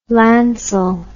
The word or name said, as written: lancel